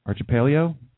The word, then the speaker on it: archipelago: Oh, here's the pronunciation. Archipelago. There we go.